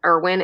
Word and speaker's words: Irwin: So if you go to Amazon, you can either type in my name, Christine Irwin. It's spelled with a K, K-R-I-S-T-I-N-E. And last name is Irwin, I-R-W-I-N.